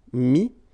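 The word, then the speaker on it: mis: I-R-E-I-T, same group. Dire, dit. Okay, then E T T R E will give you this E S, but then you don't pronounce the S, so you get I. Mettre, mi, and then E N D R E, I S, but then same thing here, the sound I.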